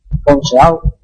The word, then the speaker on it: Ponceau: ¿Cómo pronunciaría su apellido? – Ponceau. – Ponceau. Ponceau. – Es como Jaume Ponsarnau. – Sí, no, pero versión gala.